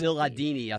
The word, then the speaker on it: Cialdini: Cialdini, I think, is his name.